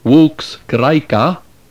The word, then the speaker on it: Vox Graeca: Allen, entitled Volksgraika, spelled V-O-X-G-R-A-E-C-A, and subtitled A Guide to the Pronunciation of Classical Greek.